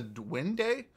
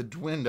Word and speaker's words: duende: They refer to them as the Duende or the Dwind. I've also heard of. I've heard it pronounced both,